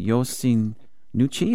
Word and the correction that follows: Yoshinouchi: is that correct? Just pronounce that correct? – Yoshinouchi.